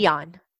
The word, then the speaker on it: Jeon: J-E-O-N. – Okay. – Jeon.